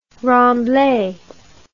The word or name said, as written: remblai